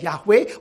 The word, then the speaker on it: Yahweh: But God is identified by his personal name, the Yod-Heh-Vav-Heh, Y-H-W-H, pronounced sometimes as Yahweh or sometimes as Jehovah.